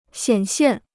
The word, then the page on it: 显现: xiǎn xiàn